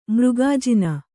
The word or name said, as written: ಮೃಗಾಜಿನ